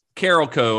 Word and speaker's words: Carolco: it's Carolco according to everyone that I've heard say it. I didn't know for years how to say it until I was watching behind the scenes on this, and that it's apparently Carolco.